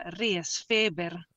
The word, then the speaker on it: Resfeber: In svedese si pronuncia Resfeber.